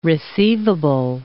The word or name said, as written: receivable